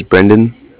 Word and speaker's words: Brendan: So my name is, okay, it's, my name is Brendan. And that's spelled B-R-E-N-D-A-N.